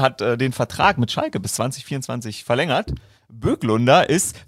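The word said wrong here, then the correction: Böklunder: Heißt das nicht Böcklunder? – Oh, spricht man nicht Böcklunder? – Ja, ich glaube.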